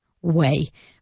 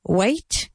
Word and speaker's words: weigh: And remember, weigh is like camino. Weigh. That's how you say pesar. Pesar is to weigh. Se escribe diferente, but the pronunciation, the important thing is the pronunciation, and it's how much do you weigh? Not weight. Weight, que suena como espera, weight, is el peso. No es el verbo. So be careful with that, because I think it's quite confusing for everybody when you're learning to speak in English.